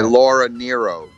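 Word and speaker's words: Laura Nyro: Was it Laura Nemo? Was dating one of the guys in the band, Jim Felder? Fielder. – Yeah, yeah. Laura Nero. Laura Nero. – What did I say? That butchered that name? You said Nemo.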